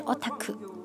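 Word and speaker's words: おたく: 现在有一个很流行的字叫做宅，意思就是说喜欢待在家里面的人，这是从日本传过来的一个词，原来的读音应该是 otaku。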